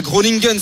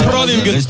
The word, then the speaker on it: Groningen: pour le joueur formé à Groningen, si ma prononciation est bonne. – Mais voilà, là tu pourras pas le prononcer en néerlandais. – Groningen. On t'écoute. – Groningen.